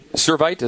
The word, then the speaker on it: Servite: Your high school, Servite, is that how you pronounce it? – Yes, sir.